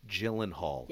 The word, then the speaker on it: Gyllenhaal: Gyllenhaal? How do you say his Gyllenhaal? – Name? Gyllenhaal.